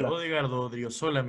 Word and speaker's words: Odriozola: cómo tiene Ordizábal de, es, es ¿cómo se dice? El, Odrio, eh, Odriozola, mi flaco.